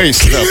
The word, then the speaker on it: пейсы: Пейсы, я правильно сказал? – Пейсы сказал, да.